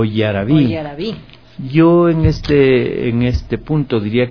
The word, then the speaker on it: yaraví: porque si decimos yaraví estamos acentuando la última sílaba, yaraví, poniéndole incluso tilde. Eso ya es absolutamente castellanizado porque en el quichua no existen palabras agudas.